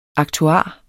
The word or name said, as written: aktuar